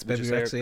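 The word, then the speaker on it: Bebe Rexha: Did you see the thing with Baby Rexha? Is that her name? How you say it? I think it's Baby Rexha, yeah.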